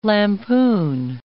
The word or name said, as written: lampoon